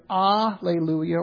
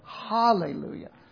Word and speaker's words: Hallelujah: Often in English, you will see the H omitted to follow that Greek transliteration. In fact, that's how it is in our English Bible here, because that's what the Greek did. It left that H off. So it's either Alleluia or Hallelujah. And Hallelujah is actually the Hebrew pronunciation.